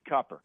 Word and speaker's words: Cooper: It's spelled Cooper, but they pronounce it Cupper.